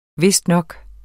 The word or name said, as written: vistnok